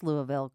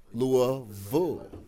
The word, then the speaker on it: Louisville: Founding members of the band are from Louisville, Kentucky, and I got a lesson from Buffalo Still on how to pronounce Louisville correctly before we got started. – Okay, so think of like rural school, like a bull, all right? Louisville.